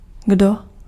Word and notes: who: American English; IPA: /hu/

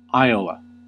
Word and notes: Iowa: American English; /ˈaɪəwə/; /ˈaɪoʊwə/

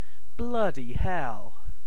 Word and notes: bloody hell: British English; [ˈblʌ.di hɛl]

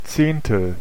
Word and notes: tenth: /ˈtɛnθ/

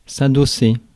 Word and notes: adosser: France; /a.dɔ.se/

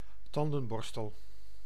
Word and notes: brush: American English

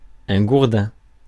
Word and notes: gourdin: France; [ɡuʁ.dɛ̃]

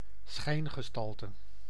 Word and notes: phase: /ˈfeɪz/

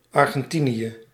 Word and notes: Argentina: /ˌɑː.dʒənˈtiː.nə/; /ˌɑɹ.dʒənˈti.nə/

